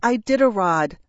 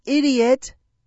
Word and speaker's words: Iditarod: I scream to deaf, uncaring ears, "It's pronounced Iditarod, not idiot!"